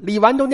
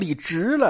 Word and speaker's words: 李纨: 但是这满嘴都是错别字儿啊，李纨都念成李直了，所以这个很可笑啊。